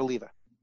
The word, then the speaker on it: Oliva: It's Jared Oliva. Right. Not Olivia. Correct.